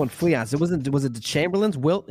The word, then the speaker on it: Fleance: Banquo and Fleance. – Fleance.